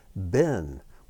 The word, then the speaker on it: Ben: And here's another word that you probably also know, and that's the word Ben, which means son. And here we see the Beit pronounced with a B sound.